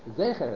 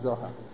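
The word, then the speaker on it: Zecher (זכר): that the word is Zecher, it's not Zohar.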